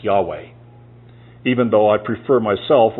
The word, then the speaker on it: Yahweh: And it should be pronounced Yahweh.